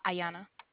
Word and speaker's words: Iyana: Ayana. – Ayana? – Yes. – How do you spell that? – I-Y-A-N-A.